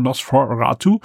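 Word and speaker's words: NOS4A2: Das wird nämlich so geschrieben, Nos, dann ein Vierer, also für Four, A und dann Two, deswegen Nosferatu.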